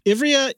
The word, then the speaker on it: Ivrea: Ivrea, Italy.